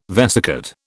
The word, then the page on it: vesicate: British English